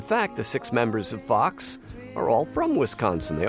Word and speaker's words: Phox: This music comes from the band Fox, that's spelled P-H-O-X.